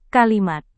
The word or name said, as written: kalimat